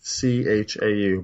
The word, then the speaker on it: Hong Chau: Hong Chau, I don't know, that's how you pronounce her name. It's Chau, but sorry in case I did butcher your name.